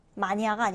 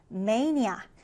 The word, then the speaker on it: mania: 자, 이 단어 발음 주의해 주세요. 마니아가 아니라 mania.